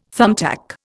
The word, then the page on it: thumbtack: Indian